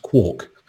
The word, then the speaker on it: Quark: sorry, Quark, so it's pronounced Quark,